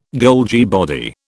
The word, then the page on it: Golgi body: British English